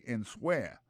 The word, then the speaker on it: answer: A-N-S-W-E-R. Alors, souta, pour un temps pour prononcer lié à tout mot, là, on tape dit answer. C'est pas comme ça, on dit c'est answer.